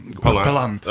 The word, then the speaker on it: Palant: Pronounce your last name for me. I'm sorry. – Exactly. It was Palant. – Okay. More emphasis on the second syllable.